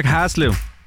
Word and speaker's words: Haslev: Og vi skal byde velkommen til dagens deltager, Haslev. Hej med dig. – Hej. – Udtalte jeg det rigtigt nok, Haslev? – Nej, Haslev.